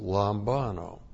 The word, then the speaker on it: lambano: L-A-M-B-A-N, long O, lambano.